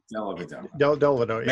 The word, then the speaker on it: Dellavedova: Where did Matthew Dellavedova I think that's how you pronounce his name. – Delavadova. – Matthew Delavadova.